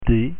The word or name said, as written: d